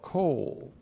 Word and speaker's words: kol: Q long O L, kol.